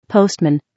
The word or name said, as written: postman